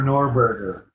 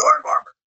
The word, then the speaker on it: Norgorber: But it's forever Norberger. – Yeah. – That's the Norberger. – You can call him Norberger to insult the cultists. Yeah. And be like, it's Norgorber.